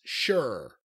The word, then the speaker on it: Sher: Indus Shure. I-N-D-U-S. And then the next word is S-H-E-R. Shure. Like John Shure, but Shure.